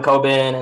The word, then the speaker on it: Coben: Harlan Coben and Scott Turow.